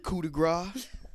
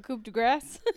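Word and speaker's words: coup de grâce: What, coup de grace? – Yeah, Corey and Kevin Patrick both say it wrong. Coup de grace. It's like, no, it's coup de grace, you freaking idiots.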